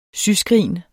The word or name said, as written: syskrin